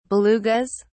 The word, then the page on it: belugas: British English